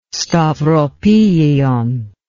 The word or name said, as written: stauropegion